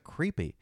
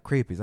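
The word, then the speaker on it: crêpe: And she's like, "What's a creepy?" She called, and I was like, "It's pronounced crepe. It's French.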